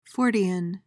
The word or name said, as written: fortean